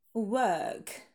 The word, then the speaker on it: work: And work, то есть именно звук шва, долгий. Work, w, да, вот этот вот нейтральный, удлиненный, так сказать.